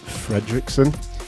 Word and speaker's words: Fredrickson: I am Jim Fredrickson. – Nice. – Pronounced with a T.